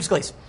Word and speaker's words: Scalise: Is it Steve Scalise? Am I getting his name wrong? Am I? Yeah, Steve Scalise.